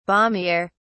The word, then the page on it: balmier: British English